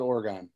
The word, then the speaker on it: Oregon: Everybody there calls it Oregon.